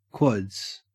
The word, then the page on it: quads: Australian English